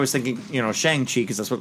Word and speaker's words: Shang-Chi: So Shang-Chi is how you say his name. So Shang-Chi.